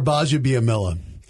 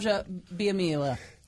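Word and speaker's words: Gbaja-Biamila: Akbar Bajabiamila. Well, there's a silent G.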